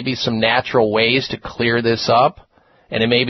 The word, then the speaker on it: Xlear: Clear Nasal Spray, and the Clear is spelled X-L-E-A-R. The X is for xylitol.